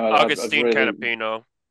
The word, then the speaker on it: Agustin Canapino: But midway through the race on a pit stop, Callum Ilott came out ahead of his teammate, the Brazilian rookie who I'm afraid I can't pronounce his name. – Agustin Canapino. – There we go.